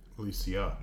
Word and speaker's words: Alesia: But essentially, it really does everything leads to uh, Elysium, right? – Yeah. – Um, I'm pronouncing that right. Yeah, it's Elysium. Alesia. Alesia, because Elysium is heaven.